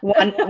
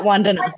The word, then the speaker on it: Vandana: Vandana. Vandana.